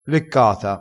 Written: leccata

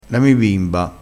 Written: bimba

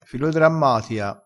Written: filodrammatia

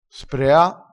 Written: sprea

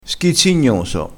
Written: schizzignoso

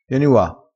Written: ua

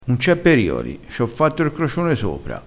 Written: facci r crocione

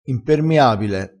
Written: impermiabile

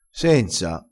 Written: senza

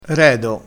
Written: redo

